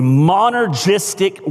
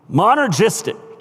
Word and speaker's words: monergistic: Our salvation is the monergistic work of God. Like that? Monergistic. Let me spell it for those of you who take notes. M-O-N as in Nancy, E-R-G-I-S-T-I-C, monergistic.